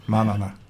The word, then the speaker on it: Manana: Een coördinatrice met de vrije naam Maniana. – Manana. – Manana. – Oh, Manana moet je daar spreken. Ja, ja, ja. Oh ja, de klemtoon ligt daar.